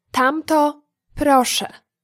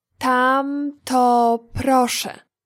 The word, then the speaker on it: tamto proszę: The phrase that please is tamto proszę. Tamto proszę. Let's break it down by syllable. Tam, to, pro, sze.